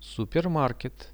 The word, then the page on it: supermarket: Russian